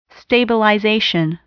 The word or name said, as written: stabilization